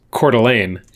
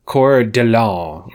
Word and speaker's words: Coeur d'Alene: The way I would read it is Coeur d'Alene and Bonner's Ferry are in the northern panhandle of this state. She pronounces something like Coeur d'Alene or Coeur d'Alene. – I missed it. – Pronounced it very, she gave it a much more French, and obviously it's from the, it is French, but yeah.